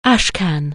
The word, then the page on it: ash can: anglais